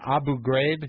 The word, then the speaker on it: Abu Ghraib: Abu Ghraib, is that how you pronounce it? – Mm-hmm.